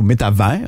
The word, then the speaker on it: Métavers: Vous avez sûrement entendu parler du fameux Metaverse ou Métavers, on le prononce en français.